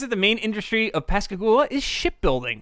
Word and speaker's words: Pascagoula: So in 1973, Pascagoula, I think that's the correct way to pronounce it, Mississippi. – Yeah.